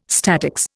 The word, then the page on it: statics: Indian